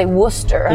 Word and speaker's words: Worcester: It's like, it's the way, you know, if you're from the Boston area, you know you're supposed to say Worcester.